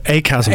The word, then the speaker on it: ACASM: Business and Partner Development Director of ABSCAM. Have I pronounced that correctly? Uh, ACASM. ACASM. ACASM. Uh, yeah, it's an acronym me.